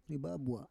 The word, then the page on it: ribabua: Roon